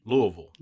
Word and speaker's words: Louisville: Louisville. – Louisville, there you go.